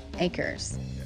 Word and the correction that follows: Achor: There's no S, is there? Shawn Achor. Oh, Achor.